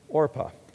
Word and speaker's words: Orpah: Her name is Orpah. Now, have you ever heard of Oprah? Oprah's name is spelled similar to Orpah, just a little bit different. It's almost Orpah.